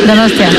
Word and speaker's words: Donostia: Donostia. – Donostia.